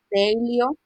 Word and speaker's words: Daylio: Tengo algunas Dailyo, que se escribe D-A-Y-L-I-O, Dailyo.